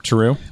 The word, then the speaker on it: Terreux: So you have the the the how do you say it the Tarot or yep. Uh, yep, Terreux.